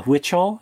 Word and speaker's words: Huichol: The Huichol is actually how you pronounce it.